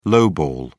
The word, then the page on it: lowball: British English